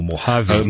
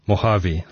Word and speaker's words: Mojave: Mac OS Mojave, I think it's called, or Mojave.